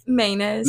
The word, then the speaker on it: Maness: I don't think that's right. You know what I mean? – Well, like, you're the decider, so. – And that's what everyone says. But like, yeah, I think that's like such a country way to do that. – Yeah, how would you say it in the South? – We say Maynes.